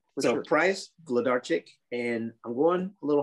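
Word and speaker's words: Włodarczyk: The W is a V. The rest of it is just like it sounds. It's Vlodarczyk. – Vlodarczyk. – Yes, like VL, Vlodarczyk. Yes. – Vlodarczyk, yeah.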